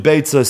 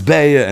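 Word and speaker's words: Beitza (ביצה): It's all rhyming with Beitza. So it seems that the proper pronunciation is probably Beitza, stating that in Yeshiva, it's called Masechtes Beya, and as a result, in Unzer Chabura, we're starting Masechtes Beya.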